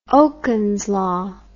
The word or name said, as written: Okun's law